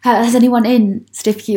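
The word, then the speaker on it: Stiffkey: I mean, Stewkie Blues are cockles, local seafood, but it's actually spelt Stifkie, and most people in Norfolk would pronounce it Stifkie. But if you were local, you would probably be allowed to call it Stewkie.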